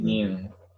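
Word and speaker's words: Yeun: Oh, with Steve, is it Yoon? Is that how you pronounce it? – Steve Yoon.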